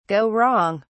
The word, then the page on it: go wrong: British English